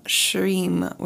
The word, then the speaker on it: Shrim: This week's mantra is Om Shrim, which is spelled S-H-R-I-M, or pronounced S-H-R-E-E-M. It's a Lakshmi mantra, and the word Shrim is just a single syllable word.